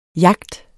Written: jagt

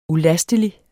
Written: ulastelig

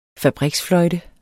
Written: fabriksfløjte

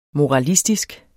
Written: moralistisk

